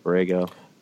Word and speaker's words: Borrego: And then James Borrego, am I saying that last name correctly, Borrego? – That's how I would pronounce it, yep.